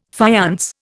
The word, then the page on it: faience: Indian